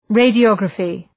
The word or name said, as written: radiography